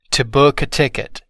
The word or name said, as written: to book a ticket